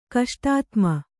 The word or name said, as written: ಕಷ್ಟಾತ್ಮ